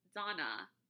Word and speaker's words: Dana: So it's spelled D-A-N-A. So for the longest time, I said Dana. Just, you know, Americanized everything. That's what Americans are wont to do. But it's pronounced Donna or Donna, and that's my mom's name.